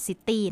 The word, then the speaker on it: city: จะไม่ออกเสียงว่า city นะ city แบบคนไทยเรียก city city city city mouse